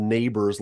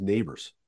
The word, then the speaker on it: Nabuurs: N-A-B-U-U-R-S. I don't know any other name with back-to-back U's. What nationality is Nabors? – It's Dutch. – Okay. – And it's actually the pronunciation in the Netherlands would be Nabors. And so my family Canadianized it a little bit. My favorite story to tell, about the two U's. So N-A-B-U-U-R-S.